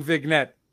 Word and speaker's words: vignette: So I just like to point out, they're also saying vignette. You guys are pronouncing the G. Right? – Vignette. – Oh, okay. No, I was just messing it up. That my bad. I heard you say vignette, but I was wrong.